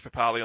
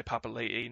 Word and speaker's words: Papali'i: Now, I'm going to put Josh Papali on the non-interesting list, because, and I should say Papali'i now, because that's how he pronounces his name.